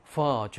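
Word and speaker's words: phở: 이제 우리가 배웠으니까 베트남 식당 가면은, 베트남 음식점 가면은, 퍼 주세요. F 발음. 퍼.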